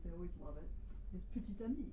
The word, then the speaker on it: petit: So you don't pronounce the second T, you don't say petit. – You say petit ami.